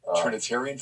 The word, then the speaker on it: Trinitarian: My uncle was had baptized me in Trinitine. I'm saying it wrong. – Trinitarian formula.